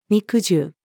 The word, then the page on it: 肉汁: female